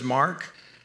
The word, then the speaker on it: Marq: My name is Mark, and I spell that M-A-R-Q, not C or K, Q as God intended.